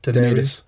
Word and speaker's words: Daenerys: Daenerys, is that how you say her name? – Daenerys.